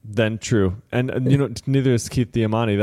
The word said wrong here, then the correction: Damiani: then. Or Damiani. Did I say that right? Damiani. I can never say his name right.